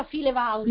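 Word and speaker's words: FileVault: File vault, lo pronuncia file vault.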